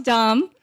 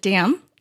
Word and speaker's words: Dam: Instead of T-R-A-N-G, it was T-H-A-N-G. So, and my last name is pronounced Dom. So a lot of Americans pronounce it Dam.